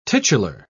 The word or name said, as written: titular